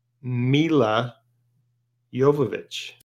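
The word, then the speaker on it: Milla Jovovich: I mean, honestly, how many times have you watched Mila Jovovich shoot a zombie before it kind of blends in? – Yeah, yeah. Oh, and I figured out how you say Mila Jovovich. It's Mila Jovovich.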